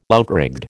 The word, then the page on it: outrigged: American English